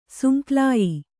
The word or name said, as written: ಸುಂಕ್ಲಾಯಿ